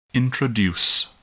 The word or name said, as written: introduce